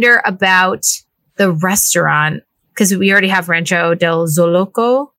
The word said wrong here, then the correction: Zocalo: Did I say that right? – Zocalo. – And Zocalo, yeah.